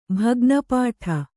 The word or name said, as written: ಭಗ್ನಪಾಠ